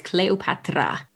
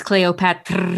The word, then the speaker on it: Cleopatra: That is Effendi with Cleopatra. Actually, no, I'm sorry. I mispronounced it. My mistake. It's Cleopatra.